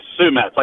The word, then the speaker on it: Sue Metz: It's Sue Metz, like a boy named Sue in the New York Mets.